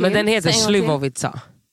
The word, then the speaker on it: Slivovica: Alltså jag tror att ni fick en Slivovica. – Slivovica? – Ja, exakt. – Ah, Slivovica.